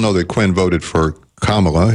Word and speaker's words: Kamala: Do you really think that Kamala Harris, voting for Kamala Harris... – It's Kamala, by the way, but keep going.